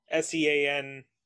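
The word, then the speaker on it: Sean Kneese: And you could also look up SEANKNEESE. That's how you spell my name. It doesn't, because niece, it's a silent K, on social media and also Instagram.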